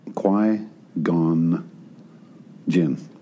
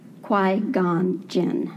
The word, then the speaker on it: Qui-Gon Jinn: Tell me how to pronounce your character's name. – Qui-Gon Jinn. – Qui-Gon Jinn.